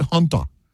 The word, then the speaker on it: Hanta: They spell it H-A-N, but they pronounce it Hunta. So, you know, some countries around the world, they pronounce A's different than we do. The A can be pronounced as a U.